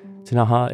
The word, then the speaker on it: Ktunaxa: Tanaha is spelled K-T-U-N-A-X-A, that explores the origins and the long history of the tribe.